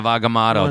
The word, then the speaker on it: Agamotto: Eye of Agamotto. That's how you say it.